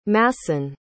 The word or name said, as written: masson